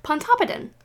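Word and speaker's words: Pontoppidan: The Pontodopidibble and Pontopidin. Oh, wait, I think that was actually right. – Pondipolum? – I accidentally said it right. Pontopidin.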